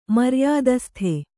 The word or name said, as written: ಮರ್ಯಾದಸ್ತ